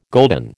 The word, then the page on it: golden: American English